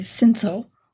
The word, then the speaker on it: Cinsault: Is that how you pronounce that? – Close. – Okay. – Yeah. – How do you pronounce it correctly? – I would say Cinsault. – Okay. That was really close then.